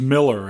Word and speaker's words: Moeller: This next artist is Gary James Miller, and he spells his last name M-O-E-L-L-E-R, but he pronounces it Miller.